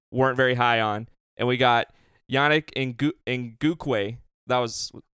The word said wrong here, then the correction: Ngakoue: Ngakoue. – Ngakoue.